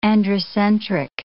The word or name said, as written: androcentric